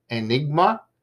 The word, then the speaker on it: Ainigma: That band is called Enigma, but it's spelled A-I-N-I-G-M-A.